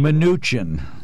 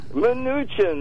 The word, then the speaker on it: Mnuchin: Munchkin. Munchkin. – Mnuchin. – Mnuchin, that's it.